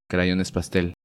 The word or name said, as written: Crayones Pastel